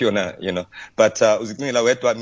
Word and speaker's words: uzicingela wedwa: Okay, so it's a Xhosa phrase for this week, or today rather, is called, uzicingela wedwa. Uzicingela wedwa. Try with me. – Uzicingela wedwa. – Uzicingela wedwa. Yes. Perfect, Lester. You spell it UZICINGELA. Uzicingela. Wedwa. WEDWA. Right.